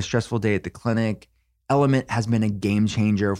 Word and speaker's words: LMNT: Have you heard about Element? It's spelled L-M-N-T.